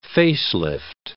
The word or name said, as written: face-lift